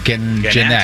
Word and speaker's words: Gennett: Cincinnati Reds second baseman, is it Scooter Gennett? Is that how you say it? – Scooter Gennett.